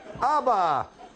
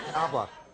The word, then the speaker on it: ABBA: ABBA. ABBA.